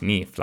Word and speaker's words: Nif: So you have to be like, hey, it's N-I-F, but it's pronounced Neef, like N-E-E-F. Because that obviously is tricky for people. It's like, oh, you know, Neef rhymes with riff or something. I'm like, no, it rhymes with leaf, like on a tree.